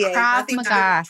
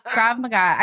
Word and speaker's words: Krav Maga: Krav Maga. – Spell it for me. – K-R-A-V and then, uh-huh, Maga, M-A-G-A. – Okay, Krav Maga. – Trump. – Okay, Krav Maga.